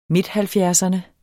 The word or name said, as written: midthalvfjerdserne